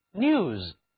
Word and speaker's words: news: 我们给 new 添一个字母 s, s 是一个名词后缀，它读作浊辅音 z, news,